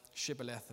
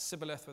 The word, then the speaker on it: Shibboleth: and they ask them to say this word, Shibboleth, and if they say it Sibboleth without the sh, they hack the person down because they know the Ephraimites can't pronounce it properly.